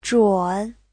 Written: zhun3

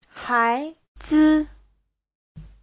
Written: hai2zi5

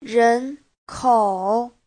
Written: ren2kou3

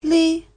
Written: li5

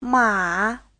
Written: ma3

